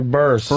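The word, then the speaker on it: Burress: How do you say Barras? Barras? – Yeah, Burst. – Burst.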